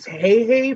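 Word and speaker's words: Hei Hei: It's Hey Hey from Moana.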